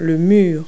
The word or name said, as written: wall